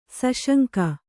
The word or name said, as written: ಸುಸಂಗ